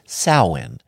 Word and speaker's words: Samhain: Or, if you want to pronounce it properly, it is Samhain.